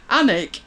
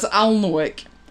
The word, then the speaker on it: Alnwick: I was given a snooty look for pronouncing Annick as Alnwick.